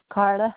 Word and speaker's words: Karla: Carla Hubbard.